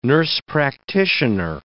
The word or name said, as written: nurse-practitioner